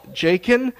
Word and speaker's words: Jakin: But anyway, as that being said, I have had people say my name wrong my entire life. I introduce myself to people. I say, hello, my name is Jakin. And I try to accentuate myself as well as I can, because without fail, they will continue to call me Jacob.